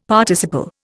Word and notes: Participle: Indian

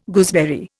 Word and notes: gooseberry: Indian

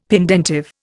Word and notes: pendentive: Indian

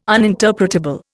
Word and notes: uninterpretable: Indian

